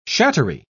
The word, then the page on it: shattery: British English